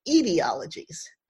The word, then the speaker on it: etiologies: I keep saying etiologies, but this word, which means an origin story, should be pronounced etiologies. The problem is I first learned the word by reading it, and I never heard it spoken until like years later.